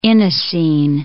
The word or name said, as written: inosine